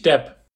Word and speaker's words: DAB: Mit deinem Bruder hast du die Online-Plattform richtiggutbewerben.de ans Fliegen gebracht und vermittelst weltweit Freizeitaktivitäten über die Videoplattform DAB. Wie spricht man es eigentlich aus? – Genau, es ist richtig, DAB.